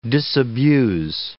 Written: disabuse

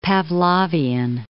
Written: Pavlovian

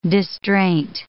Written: distraint

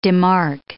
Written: demark

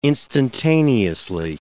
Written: instantaneously

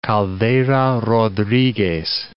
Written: Caldera Rodriguez

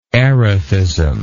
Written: erethic

